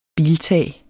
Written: biltag

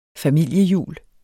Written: familiejul

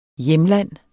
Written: hjemland

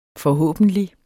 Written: forhåbentlig

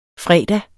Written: fredag

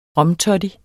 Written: romtoddy

